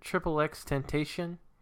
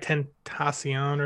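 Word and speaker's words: XXXTentacion: Is it is it Triple X Temptation? – it's like Tentacion or something.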